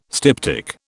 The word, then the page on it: styptic: British English